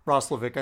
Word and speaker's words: Roslovic: Roslovic, I think, is actually the way it's pronounced.